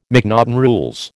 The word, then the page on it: McNaughten rules: American English